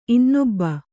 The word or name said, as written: ಇನ್ನೊಬ್ಬ